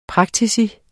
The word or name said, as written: PRAKTIKUS